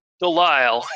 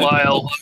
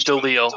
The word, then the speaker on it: Delisle: Delisle. In the U.S., Delisle. It would be Delisle. In French, it would be Delisle. But I'm from the U.S.